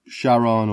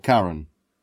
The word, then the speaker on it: Charon: So, Charon or Charon, depending on where you're from.